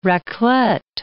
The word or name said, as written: raclette